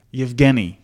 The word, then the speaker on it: Yevgeny: Yevgeny? Is that the right? Did I say it right? Yevgeny. – That's right. That's his name.